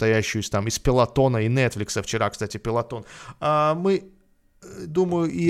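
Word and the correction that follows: Peloton: Вчера рухнули акции Пелотон. Пелотон, да, пишется.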